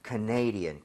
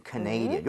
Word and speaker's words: Canadian: 왜냐면 캐나다는 Canada 이렇게 가는데 네. – 이게 캐나다 사람 이름에는 Canadian 강세가 뒤로 하나 밀려요. 그래서 살짝 헷갈릴 수 있습니다. – Yes. – So Canadian, Canadian.